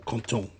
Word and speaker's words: content: I would translate it as essayons de rendre Paddy content. Or content, sorry, don't pronounce T.